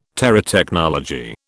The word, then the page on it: Terotechnology: British English